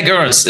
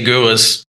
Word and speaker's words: Garris: Yeah, Garris. It's actually a thing, right, to say that name right. It's, in German, I would say Garris.